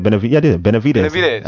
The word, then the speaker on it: Benavidez: Saturday night, Terrence Bud Crawford TKO'd Jose Benavidez Jr. Did I say it? Benavidez. I said it right.